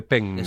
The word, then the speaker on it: penne: no, eh, porque es eh, penne. – Es con doble N. – Exactamente. – Y la pronunciación es diferente.